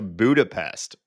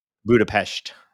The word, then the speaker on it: Budapest: I'm going to Budapest for work, which is... – You're not saying it right. – Budapest. Sorry. Pest. I'm so sorry.